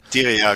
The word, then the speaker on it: Diriyah: Diria, so wird es ausgesprochen, oder? Hast du gerade gesagt? – Diria, genau.